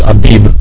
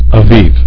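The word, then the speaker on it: Aviv (אביב): The King James says Abib, but it's a bait without the bagish in the center, the little point in the center, and so it's pronounced with a vait, not as a bait. Okay, it's a V sound.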